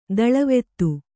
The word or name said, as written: ದಳವೆತ್ತು